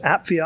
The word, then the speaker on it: Apphia: Apphia you'd pronounce the, the, the, P and the PH separate. Those are two separate letters. So Apphia, our sister.